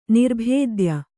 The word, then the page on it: ನಿರ್ಭೇದ್ಯ: nirbhēdya